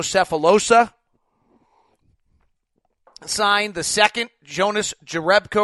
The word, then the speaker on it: Jerebko: It's Jonas Jerebko. is the pronunciation uh, according to Basketball Reference.